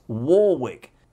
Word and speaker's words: Warwick: One of the most mispronounced surnames in pop music is Warwick or Warwick. And I have it on very good authority that it is Warwick.